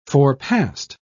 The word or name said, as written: forepassed